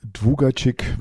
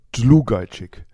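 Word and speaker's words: Długacik: Und in Deutschland gibt es dieses L mit einem Strich oben drüber nicht, deswegen wird das wird wie ein W ausgesprochen im Polnischen. Und so wird dann aus Dwugacik wird dann Dlugacik.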